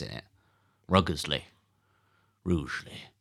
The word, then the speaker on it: Rugeley: Rougeley, which you've learned how to pronounce. – I've learned how to pronounce that very recently. – R-U-G-E-L-E-Y, those of you who are taking notes. – Is that correct? – Yeah.